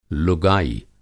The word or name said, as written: लुगाई